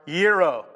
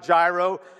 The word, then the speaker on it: gyro: It's not gyro. I ordered those things, and I've said it wrong so many times. I had to play that back online like 14 times. Gyro. Gyro. That's what they're called.